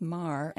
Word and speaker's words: mar: In English, it means, of course, a flaw, and it's pronounced mar. And how's it pronounced in Portuguese? – Mar. – Oh, so beautiful. And if you're really from Rio, it'd be mach, and it means the ocean.